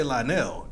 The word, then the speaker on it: Lionel: We don't even really pronounce the O. We really just say Lionel. It's just the Lionel, like N-U-L-L.